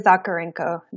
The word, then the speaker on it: Zakharenko: And my last name is Zakharenko. The H is silent.